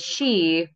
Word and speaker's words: sidhe: Like, so the she, which is spelled SIDHE in like Irish or Celtic or whatever, like means just like the underworld.